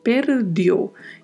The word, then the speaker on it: perdeu: Perdeu. E atenção à pronúncia. Di, não temos som de di, di. E o O é também fechado, um som fechado.